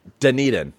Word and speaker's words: Dunedin: It's like how I can't say Dunedin. There you go. Said it right.